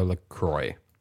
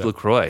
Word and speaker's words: LaCroix: So there was a long debate in our writer's room if it was LaCroix or LaCroix. – It is LaCroix. – It's LaCroix. – Because it rhymes with enjoy.